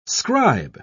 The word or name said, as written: scribe